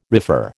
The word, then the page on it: refer: American English